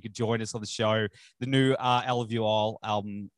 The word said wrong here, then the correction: Alluvial: Did I say that right? I've never. – Nope. – Alvuel. – Alluvial. – Alluvial. See? – Yeah.